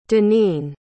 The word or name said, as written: daneen